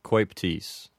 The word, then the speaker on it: coeptis: So in this case, the O and the E go together and sound like oi. So as far as my knowledge goes, this expression would be pronounced anuit coeptis.